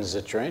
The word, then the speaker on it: Zittrain: Jonathan Zittrain, am I saying it right? – Yep.